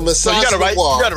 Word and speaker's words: misogynoir: And he talked about menage misogynoir. I think I'm saying that wrong. It's misogyny and noir. So misogynoir.